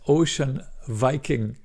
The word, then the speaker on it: Ocean Viking: Genau, die Ocean Viking, dieses Flüchtlingsschiff oder das Schiff, was die Flüchtlinge aufgenommen hat, die Ocean Viking, das heißt Ocean Viking, ja, das ist ein V. Das spricht man nicht Hur, nicht Viking.